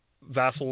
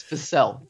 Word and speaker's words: Vassell: I think particularly, Vassal or slash Vassell, how I can't decide how to say it. – It's Vassell. – Is it Vassell? – Vassell. Okay. I might have said Vassal.